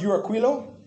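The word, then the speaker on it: Euraquilo: It's pronounced Uraquilo.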